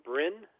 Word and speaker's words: Bryn: My name is Bryn, B-R-Y-N, Bryn.